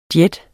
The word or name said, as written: jet